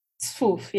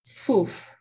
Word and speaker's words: Sfouf: Is that how you pronounce it? Sfouf? – Sfouf, yeah. – Say it one more time. – Sfouf. – Sfouf. Sfouf. Yeah. Okay, you put the S and F together a little bit more than I was.